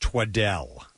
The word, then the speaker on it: Twaddell: T-W-A-D-D-E-L-L. – All right. I think it's pronounced twaddle. – Oh, well, I like twaddle.